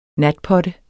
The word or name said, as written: natpotte